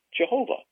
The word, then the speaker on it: Jehovah: The four letters which constitute the name of God, and the over-Englishized version of the pronunciation is Jehovah.